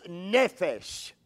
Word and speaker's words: nephesh: It is nephesh. It is spelled N-E-P-H-E-S-H, nephesh.